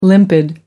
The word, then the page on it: limpid: American English